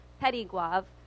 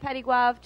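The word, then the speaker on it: Petit-Goâve: I teach primarily English and Bible at Christian Academy of Petit Goave. The last T is silent because it's French and you only pronounce half of the letters that you see. And yes, Christian Academy of Petit Goave, to abbreviate, we go by CAP, just to keep it simple.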